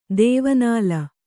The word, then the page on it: ದೇವನಾಲ: dēva nāla